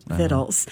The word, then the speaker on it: victuals: And so instead of spelling it phonetically, they spelled it V-I-C-T-U-A-L-S. But continue to pronounce it Vittles.